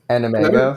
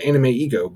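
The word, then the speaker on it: AnimEigo: Animego. – I've been pronouncing it Anime Ego, but I think it's supposed to be like Anime and Ego combined, like Ego being it.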